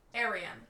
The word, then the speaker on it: Aryan: Okay, that's probably something they don't get a lot, but, Aryan. So we were pronouncing it right.